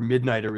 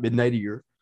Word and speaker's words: Midnighter: The authority member, Midnighter, is not Midnightier. but he kept doing that. And it was just like, I kept being like, you're driving me nuts, man. Like, that's not the way it's pronounced.